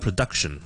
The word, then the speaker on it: production: 正如我哋讲 production 可以制作呢个字咧，我哋就唔会读成 production， because duck 先系重音，production 前面嗰个咧我只会轻读成咧 production。